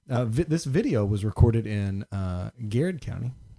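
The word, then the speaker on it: Garrard: But it's spelled Girard County. – Right. It's Garrett County.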